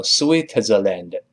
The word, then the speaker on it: Switzerland: Switzerland，当然李老师先拼起来叫 S W I T Z E R L A N D，再来一遍 S W I T E R L A N D，跟老师读一下 Switzerland，Switzerland，注意不要读成 Switzerland。